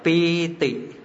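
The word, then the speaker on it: pīti: Chữ này đọc là pi tỷ, dịch là phỉ.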